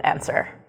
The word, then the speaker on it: answer: Well, you might notice that the W here is silent in the word answer. – Yeah, so We Gave You the Answer, W is silent, and maybe that's going to come in handy for some of our stuff a little bit later on.